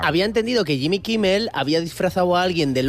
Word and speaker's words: Jimmy Kimmel: Mandó ese vídeo a Jim Kimmel. Como los americanos tienen pasta gansa. – Jimmy Kimmel. – Bueno, tú pronuncias bien, yo lo no, hago de rollo de Menorca.